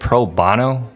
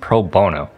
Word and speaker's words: pro bono: Pauly, you said pro bono. It's actually pronounced pro bono. Only Bono from U2 pronounces bono like that.